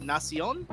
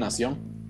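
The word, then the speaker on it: Nación: I think it's pronounced Nacion. Nacion Lucha Libre. – Oh, like Nation Nacion? – Yeah.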